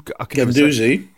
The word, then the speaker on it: Guendouzi: Guendouzi.